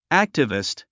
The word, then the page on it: activist: ǽktəvist; アァクチビスト